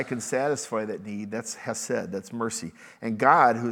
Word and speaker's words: chesed: In the Bible, the Hebrew word for mercy is chesed. It's spelled like C-H, but it's pronounced with that Jewish ch sound, chesed.